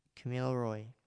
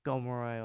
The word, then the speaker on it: Kamilaroi: and Kamilaroi, or people pronounce it as Gomeroi or Gamilaroi, but I've grown up knowing just Kamilaroi, and a lot of my family pronounce it that way, so that's how it fits with me.